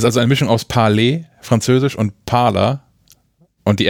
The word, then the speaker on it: Parler: Also das ähm, Amazon, äh, pa wie, wie, spricht man das aus? Parler. Aus es ist Französisch, oder?